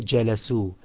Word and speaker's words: جلسوا: This alif, it's not actually pronounced, it is merely in writing. It's the waw that is actually pronounced. So you say الطالب جلس والطلاب جلسوا.